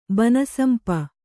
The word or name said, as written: ಬನಸಂಪ